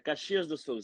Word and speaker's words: Caxias do Sol: Tu nasci in Brasile, perdonami la pronuncia, credo si pronunci Caisas del Sol. Dove... – Quasi, quasi, quasi l'hai seccata. Caxias do Sol si dice.